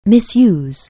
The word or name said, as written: misuse